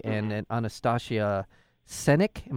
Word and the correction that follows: Sendik: Am I saying that correctly? Uh, Sendik. Sendik. Pardon me.